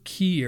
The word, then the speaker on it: Quay: And it was in the River Quay area. That's spelled Q-U-A-Y. It looks like Quay.